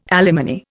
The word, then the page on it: alimony: Indian